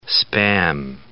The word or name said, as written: Spam